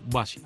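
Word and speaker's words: Basia: Me da mucho placer presentarte a Bacha. Bacha se escribe con B y con S. Czechelewska con T. Czechelewska. Ahí te lo dejo. Olvídate del apellido. Bacha.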